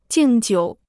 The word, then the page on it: 敬酒: jìng jiǔ